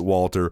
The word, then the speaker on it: Walther: There's a gun in the British made gun called Walter, and it's spelled W-A-L-T-H-E-R. You pronounce it Walter.